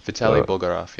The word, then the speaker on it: Vitaly Bulgarov: So I don't know how to pronounce his name. Vitaly uh, Bulgarov. Yeah, you said it right.